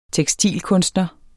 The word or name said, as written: tekstilkunstner